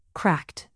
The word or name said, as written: cracked